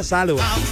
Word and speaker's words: Salwa: Y me dijeron, se pronuncia Salwa. Ojo, se escribe Salwa, pero se pronuncia Salwa.